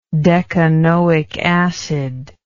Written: decanoic acid

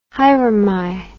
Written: haere mai

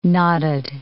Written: knotted

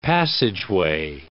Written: passageway

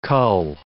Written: cull

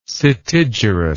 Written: setigerous

